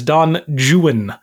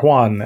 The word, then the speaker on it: Don Juan: Tula got it correct with what is Don Juan or Juan as it should be. But Ken informed everyone that Byron pronounced it Don Juan. – The rhyme scheme only works if you pronounce it Juan, which is so annoying.